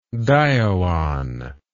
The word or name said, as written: dioon